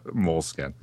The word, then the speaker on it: Moleskine: Field notes are on that list, of course, and of course the ever ubiquitous, loved and scorned by all, Moleskine. – Oh, don't say it like that. – Moleskine. – There you go.